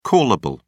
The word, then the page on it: callable: British English